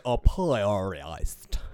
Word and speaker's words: Apiarist: I asked Google and it said apiarist.